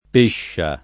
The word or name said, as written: Pescia